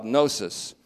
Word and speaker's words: gnosis: That this knowledge, and it's called gnosis, I'll just put a couple simple words up here. G-N-O-S-I-S. Gnosis is how it would be pronounced in the Greek, long O.